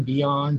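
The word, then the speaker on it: Björn: That's how you pronounce it, and Bjorn, B-J-O-N. Bjorn, that's how it is pronounced.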